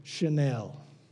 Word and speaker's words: Chanel: Let's take this perfume, Chanel. You have to say it like that because of the commercials.